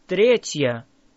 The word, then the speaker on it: Третья: Listen carefully. Третья. Третья. Say тє. Say є. Тє. Третє. Третє. Третья.